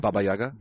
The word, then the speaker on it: Baba Yaga: How do you say it, Maestro? – Baba Yaga. – Baba Yaga. – Baba Yaga. Sorry.